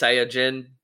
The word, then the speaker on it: Saiyajin: And in the Japanese language, you would say it's Saiyajin, but it's pronounced, or Saiyajin. But in the Funimation animation English dubs, it's pronounced Saiyan.